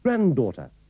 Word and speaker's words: granddaughter: En cambio, en la pronunciación inglesa de abuelo, abuela y nieto y nieta, no se pronuncia la letra D o solo se pronuncia una, como notará en la última palabra. – Grandfather. Grandmother. Grandson. Granddaughter.